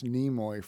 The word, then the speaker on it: Nimoy: No, it's the proper pronunciation. It's like tsunami. Tsunami. – It's Leonard Nimoy. – Nimoy. – It's been pronounced Nimoy for decades.